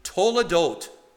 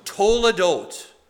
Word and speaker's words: toledot: This is the word in Hebrew pronounced, and say it after me, toledot. Toledot. Yeah, like you're saying, hold the door.